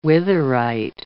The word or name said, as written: witherite